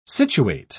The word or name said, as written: situate